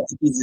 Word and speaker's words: Equis: Equis, if I'm uh, pronouncing that correct, correctly. So Equis is X in Spanish.